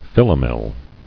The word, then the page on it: Philomel: phil·o·mel